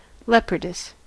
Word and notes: leopardess: American English

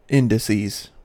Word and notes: indices: American English

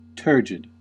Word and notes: turgid: American English

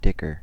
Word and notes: dicker: American English

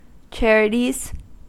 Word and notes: charities: American English

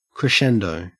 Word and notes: crescendo: Australian English

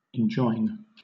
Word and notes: enjoin: British English

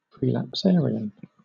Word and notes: prelapsarian: British English

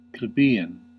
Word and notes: plebeian: American English

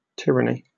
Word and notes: tyranny: British English

